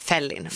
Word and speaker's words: Felin: Sen är tanken att det ska uttalas så liksom amerikansk fällin.